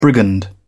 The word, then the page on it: brigand: British English